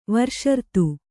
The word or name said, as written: ವೃಷರಥ